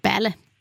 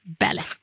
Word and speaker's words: Balle: Ja, och Solveig heter, det stavas B-A-L-L-E, men uttalas Bälle. Bälle.